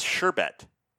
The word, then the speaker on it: sherbet: I could have sworn it was sherbet. It's sherbet.